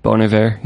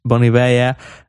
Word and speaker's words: Bon Iver: So first of all, he brought out how do you pronounce him again? Bon Iver? – Bon Iver, yeah. – Bon Iver, yeah.